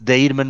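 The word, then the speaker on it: Değirmen: Tolga, T-O-L-G-A, Deirman. Lê-se Deirman, mas é com G, é Deirman, é assim que se escreve.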